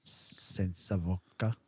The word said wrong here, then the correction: Voce: Sense of Voce.